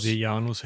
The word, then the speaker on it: Seianus: Da hat er dann ganz entspannt auf seinen Prätorianerpräfekten äh, Seianus oder Seianus, wie spricht man den aus? Seianus hätte ich gesagt.